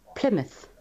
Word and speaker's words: Plymouth: La boca desaparece ahí. O sea, se escribe exactamente igual que boca. Es que estas son las cosas que confunden a la gente, claro. Se escribe como boca, pero no suena nada parecida. Plymouth.